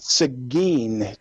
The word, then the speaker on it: Seguin: Did I say that right? Sequin? – It's actually Seguin, Texas. – Seguin, Texas. – Yeah, it looks like Sequin, but it's Seguin.